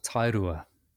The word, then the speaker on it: Tairua: Yeah, I live in a small beach town called Tairua.